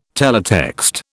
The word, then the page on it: teletext: British English